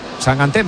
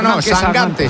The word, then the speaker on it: Sangante: Sangante, se pone Sangante. – Yo pronuncio como me digáis. – Bueno, eso he leído yo, que dice que es Sangante. – De hecho, me ha dicho, tú acuérdate de Mangante.